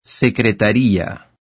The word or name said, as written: secretaría